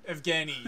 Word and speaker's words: Evgeny: Evgeny, yes.